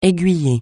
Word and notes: aiguiller: français

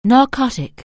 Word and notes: narcotic: anglais

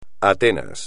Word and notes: Atenas: espagnol

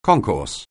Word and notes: concourse: anglais

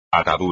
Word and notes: atadura: espagnol

